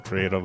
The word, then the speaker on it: Prieto: Shout out to Isaac Prieto, by the way. And I know I pronounced that wrong. I got to roll that Prieto. – R.